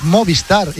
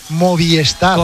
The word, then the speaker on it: Movistar: Y Sepp Kast, oye, y hay mucha gente que pronuncia mal Movistar. Es Movistar. Y la gente dice Movistar.